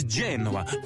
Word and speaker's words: Genoa: This meaty classic comes loaded with pepperoni, Genoa salami. – No, it's Genoa. Emphasis on the J.